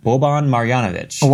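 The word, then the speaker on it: Boban Marjanović: Boban Marjanovic.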